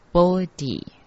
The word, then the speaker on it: Bodhi: 第四句，Bodhi sattvaya，菩提念成 Bodhi。